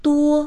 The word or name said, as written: duo1